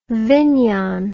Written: vinyon

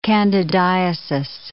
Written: candidiasis

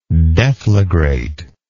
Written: deflagrator

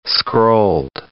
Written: scrolled

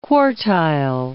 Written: quartile

